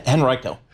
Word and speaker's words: Henrico: Henrico? – Henrico.